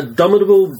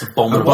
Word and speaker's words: abominable: Um, he also appeared in the uh, uh Abominable Doctor Fives. Abominable. – Abominable. – Abominable. Abominable. – Like As in snowman. you put the wrong emphasis on the wrong syllable.